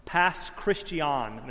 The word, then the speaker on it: Pass Christian: Pass Christian, Mississippi.